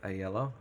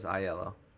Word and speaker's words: Oyelowo: David, is it Oyelowo? How do you pronounce his name? – Oyelowo. I always thought it was Iello. – No, because it's Lowo. Oh. Lowo. Oyelowo. Somebody help us. We're gonna. The other officer. Chinanza, I'm going to kill it. U-C-H-E.